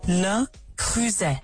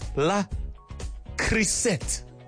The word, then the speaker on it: Le Creuset: I'm going to play you the correct pronunciation. – Le Creuset. – Yes. Le Creuset. I don't know how many times I used to pronounce it as La Creuset.